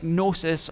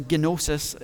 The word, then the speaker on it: gnosis: The, the most common word for knowledge is gnosis or gnosis. If, uh, I don't know how a Greek would actually say it. Your Greek teachers tell you to pronounce the G, and I just think that sounds dumb.